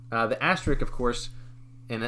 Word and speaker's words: asterisk: So it's spelled A-S-T-E-R-I-S-K. – So and a lot asterisk. – Asterisk. – Asterisk. – Asterisk. So the K is supposed to be the last part.